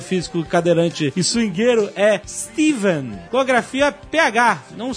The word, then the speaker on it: Stephen: A grafia PH é apenas uma versão antiga do nome escrito com V, Steven. A pronúncia nos dois casos é a mesma, com V.